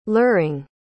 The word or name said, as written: luring